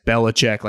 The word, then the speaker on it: Belichick: Maybe Mike Vrabel, kind of like Belichick, just figures out when I say Belichick, like it's three different syllables, just a, a way to get it done.